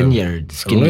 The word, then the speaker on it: Lynyrd Skynyrd: Lynyrd Skynyrd.